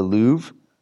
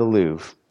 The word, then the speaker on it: Louvre: And because of that, they have, and Kelly and I have been arguing over how to pronunciate this, the Louvre. I said it right. They have the Louvre.